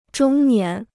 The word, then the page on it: 中年: zhōng nián